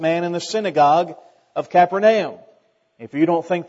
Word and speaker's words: Capernaum: Most people pronounce that Capernaum, but when I was in Israel, the tour guide said it was Capernaum, the city of Nahum, and that's why it has stuck with me ever since.